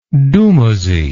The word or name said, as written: dumuzi